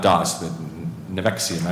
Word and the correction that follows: NXIVM: I never know how to pronounce it. But Nexium, correct. – Nexium.